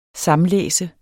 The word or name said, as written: samlæse